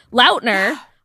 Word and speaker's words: Lautner: I mean, literally, I think it was Summit just posted a video of fucking Taylor Lautner, Lautner I found out we've been saying his last name wrong this entire time. Taylor Lautner nice. saying, "Where the hell have you been, Loca?" again, and it's he just said it like, on Jimmy Kimmel.